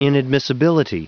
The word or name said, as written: inadmissibility